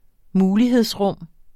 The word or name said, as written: mulighedsrum